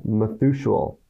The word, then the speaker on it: Methuselah: Methuselah.